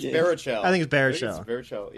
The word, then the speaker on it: Baruchel: Jay Bruchnell's great in it. Jay Bruchnell? Am I saying it right? – I think it's Baruchel. Yeah, he's Canadian.